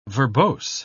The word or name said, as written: verbose